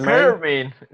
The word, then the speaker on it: Garmin: E, bo czytałem o tym właśnie, że Garmin, tak to się wymawia? Tak, Gar, gar, Germin. – Germin.